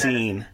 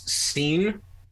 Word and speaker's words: Cine: That's my 32nd pronounced Cine. Yeah. – Okay. I have them taking Lewis Cine out of Georgia.